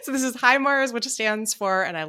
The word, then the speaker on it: HIMARS: I actually have no idea how people pronounce this acronym. Is it HIMARS? – HIMARS.